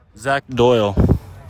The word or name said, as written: Zach Doyle